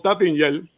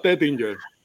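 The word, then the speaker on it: Taittinger: En, en americano boricua le decimos Tattinger, pero se pronuncia Tettinger.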